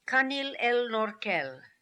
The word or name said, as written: Kanil El Norkel